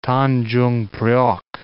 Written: Tanjungpriok

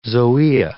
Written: zoea

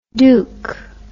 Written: douc

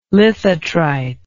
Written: lithotrite

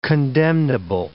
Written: condemnable

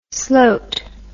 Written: sloat

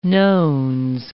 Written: nones

